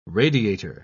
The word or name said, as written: radiator